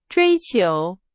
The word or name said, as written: 追求